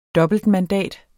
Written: dobbeltmandat

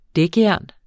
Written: dækjern

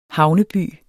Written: havneby

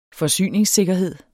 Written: forsyningssikkerhed

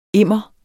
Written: immer

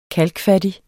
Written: kalkfattig

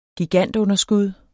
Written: gigantunderskud

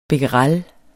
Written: becquerel

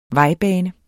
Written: vejbane

